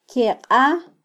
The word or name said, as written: kyeqa